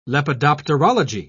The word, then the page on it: lepidopterology: British English